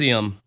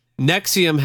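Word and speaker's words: NXIVM: So Nivixim, or how do you say it? Nexium. – Nexium had all the hallmarks of a money scamming cult, but the truth was far, far worse.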